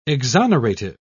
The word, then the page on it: exonerative: British English